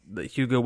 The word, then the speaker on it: Hugo: Hugo. Yeah, I like how you still say it wrong even after I just said it.